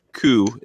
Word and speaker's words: Koo: I believe that's pronounced Koo. – I believe so, yes.